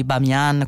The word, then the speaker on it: Bamiyan: Sì, no, io stavo pensando ai Buddha erano di Bamiyan quando iniziò. – Sì, assolutamente, in Afghanistan, detto giusto.